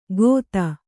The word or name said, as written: ಖೋತ